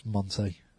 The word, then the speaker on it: Monte: It's Monte, isn't it? – Yeah. – Oh. – It's pronounced Monte. – Is it? So it's like Del Monte? – A little bit.